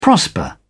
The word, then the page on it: prosper: British English